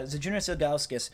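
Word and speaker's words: Žydrūnas Ilgauskas: Yeah, Zydrunas Ilgauskas.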